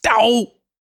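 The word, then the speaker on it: DoH: You got to stop mispronouncing that. It's DOE!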